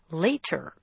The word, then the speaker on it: later: Take the word spelled L-A-T-E-R. In natural conversation, English speakers do not pronounce it later. They say later. – It's almost like a D. Pronouncing it with a very precise T sounds too correct.